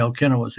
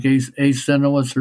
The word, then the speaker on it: Alcinous: They mispronounce his name, by the way. It's supposed to be Alcinous. They pronounce it something like Acinous or something like that.